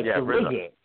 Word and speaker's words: RZA: Yeah, RZA.